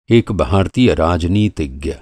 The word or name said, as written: राजनीतिज्ञ